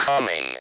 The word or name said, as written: coming